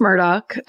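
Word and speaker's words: Murdaugh: It's pronounced Murdoch, I learned.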